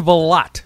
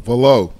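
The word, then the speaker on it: Valot: Florian Vallot. Did I say that right, Mr. Crenshaw? – No, Vallot. You weren't listening to Conti. He called it Vallot. He pronounced it Vallot.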